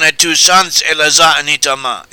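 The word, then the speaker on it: Itamar (איתמר): Eleazar was in charge of this work of the clan of Kahat, whereas Itamar is how the name is pronounced in modern Hebrew. In the Bible, it is, or in our biblical translation, this name Itamar is given as Ithamar, which is probably closer to how the original Hebrew was pronounced.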